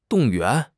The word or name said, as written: 动员